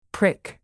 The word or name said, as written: prick